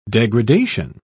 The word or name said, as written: degradation